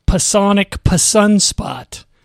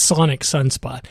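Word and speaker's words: Psonic Sunspot: And that's from Chips from the Chocolate Fireball, or it's from the album that is called Pasonic Sunspot. Actually, the P is silent, so it's Sonic Sunspot.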